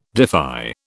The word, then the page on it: defy: British English